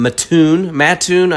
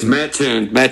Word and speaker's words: Mattoon: Someone who's probably been to Mass's a few times on his way through from Mattoon. I always get it wrong. – It's Mattoon. You pronounce both T's.